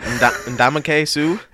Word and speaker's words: Ndamukong Suh: Ndamukong Suh? – Yeah.